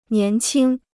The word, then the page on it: 年轻: nián qīng